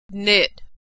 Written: knit

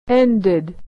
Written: ended

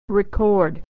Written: to record

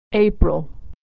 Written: April

